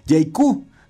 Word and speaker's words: JAECOO: Y bueno, eh, también el día de hoy le, le, tengo información de una nueva marca que se llama JQ. Se escribe J-A-E-C-O-O.